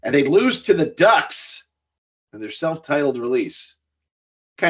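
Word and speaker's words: Duhks: It's spelled D-U-H-K, and it's a family band like the Rankin family. They call themselves Ducks, yeah.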